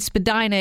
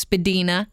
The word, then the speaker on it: Spadina: And Spadina is pronounced Spadina. It's a native yes. word meaning uphill, not a spadina.